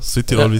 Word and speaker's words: Südtirol: Südtirol, ja.